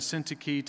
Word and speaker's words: Syntyche: Now, that's how I researched linguistically how to say their names. If you want to say it some other way, have at it. I'm fine with it. Rock on. But I'm going to go with Euodia and Syntyche.